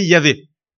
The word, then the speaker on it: Yahvé: Yahvé.